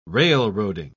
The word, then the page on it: railroading: British English